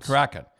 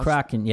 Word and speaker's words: Kraken: Kraken. – That's supposed to be a monster, eh? There's a Kraken, yeah.